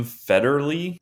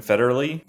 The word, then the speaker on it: Federle: You, how do you pronounce this guy's last name? Tim Federle. I would go with that. Tim Federle.